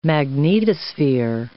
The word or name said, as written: magnetosphere